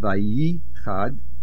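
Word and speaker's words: Vayichad (וַיִּחַדְּ): The main accent is on the Yud Vayichad,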